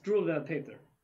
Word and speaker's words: Struwwelpeter: This is a reference that will later be referenced of, how do you say it? Struwwelpeter. Yeah, Struwwelpeter,